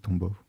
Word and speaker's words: Tombaugh: Et ensuite, la même histoire se répète, c'est-à-dire par le calcul, en 1930 pour Pluton, avec Lowell et Tombaugh. Je ne sais pas comment ça se prononce. Mais ouais, voilà. Ouais, c'est un Clyde Tombaugh.